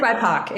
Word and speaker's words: BIPOC: I thought it was BIPOC. – No, it is BIPOC.